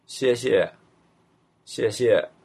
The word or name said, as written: xiexie